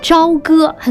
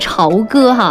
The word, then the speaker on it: 朝歌: 那接下来我们来继续跟大家来分享几个地方，那就是我相信很多朋友听说过这样的一个就是古称，叫做朝歌，很多朋友可能会读成朝歌哈。那么提到这个就是朝歌呢，哎，会想到什么呢？就是这个封神演义，纣王、妲己、比干，哎，这些耳熟能详的人物和故事，让这个朝歌呢，哎，就是蒙上了一层浓厚的这个就是神话色彩。